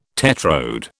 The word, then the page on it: tetrode: British English